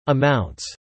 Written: amounts

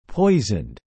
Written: poisoned

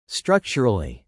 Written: structurally

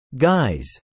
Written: guise

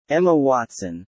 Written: Emma Watson

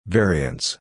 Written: variants

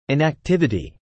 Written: inactivity

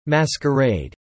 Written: masquerade

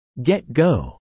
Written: get-go

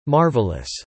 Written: marvelous

